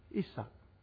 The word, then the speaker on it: Isaac: Out came Isaac. Ishak. I'm trying to pronounce it the Jewish way. Ishak.